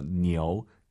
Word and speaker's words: 牛: 니우 아니고 아이유 사이에 오 넣어서 니오, 니오, 니오파이 이렇게 발음하시기 바랍니다.